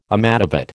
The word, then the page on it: amadavat: American English